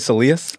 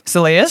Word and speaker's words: soleus: Let's double click on the, how do you say it? Soleus? – Soleus. – Soleus. American, I say soleus.